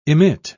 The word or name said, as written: emit